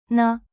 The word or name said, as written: ne